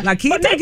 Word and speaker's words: La Quinta: La Quinta gives you a good little deal, girl.